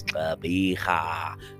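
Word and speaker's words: Gqeberha: The new name of Port Elizabeth is Tlabiha. That is how they pronounce it.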